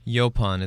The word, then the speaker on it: Yaupon: Yoplum? – Yopon. It's spelled Yaupon.